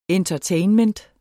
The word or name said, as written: Entertainment